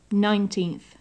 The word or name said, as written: nineteenth